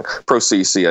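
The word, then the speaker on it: Prosise: Yeah, they'd probably cut him off at like 25 and maybe put ProSice in there or something. ProCC, I mean to say. I didn't mean to offend the family saying it wrong.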